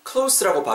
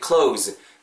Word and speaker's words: close: close라고 발음하시게 되면은 가까운이라는 뉘앙스의 형용사로서 뜻이 전해질 수가 있어요. 그러니까 는 close, z 발음으로 끝나야 합니다.